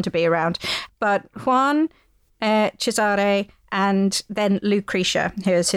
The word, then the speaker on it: Cesare: and Cesare, which is spelt Caesar, but pronounced Cesare.